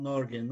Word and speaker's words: Norge: Sonnen Av Norge, Norg ya da nasıl okunuyor? Norge diye okunuyor değil mi? – Sondan Av Norge, Norveç'in evlat oğulları belki.